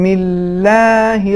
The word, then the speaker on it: اللہ: اگر بسم اللہ سے پہلے حرکت زیر ہوتی ہے یعنی کسرہ ہوتا ہے تو خود لفظ جلال اللہ نازک انداز سے تلفظ ہوگا, جسے ہم ترقیق کہتے ہیں یعنی نازک انداز میں. تو اس کا صحیح تلفظ اس طریقے سے ہے, بسم اللہ الرحمن الرحیم.